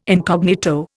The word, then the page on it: incognito: Indian